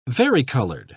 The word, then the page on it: varicolored: British English